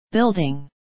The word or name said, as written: building